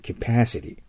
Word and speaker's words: capacity: capacity、 a の発音に気をつけましょう。そこにアクセントがあるときにはアメリカイングリッシュでは強く後への中間の音を出します。 capacity です。